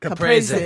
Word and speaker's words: Caprese: Caprese. – Caprese, yes.